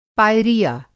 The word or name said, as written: पायरिया